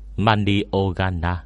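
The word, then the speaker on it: Manioganna (万葉仮名): Tiếng Nhật đọc là Manioganna.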